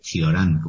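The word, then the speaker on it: Cioran: que según una amiga mía rumana, Ciorán es Chiorán, como se pronuncia, ¿no?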